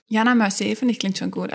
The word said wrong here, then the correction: Mercier: spricht man nicht so aus. Ich glaube, das ist Französisch. Mercier.